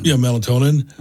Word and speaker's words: melatonin: melanin? What is that right? Am I saying that right? Yeah. Melatonin.